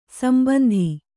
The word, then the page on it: ಸಂಬಂಧಿ: sambandhi; Kannada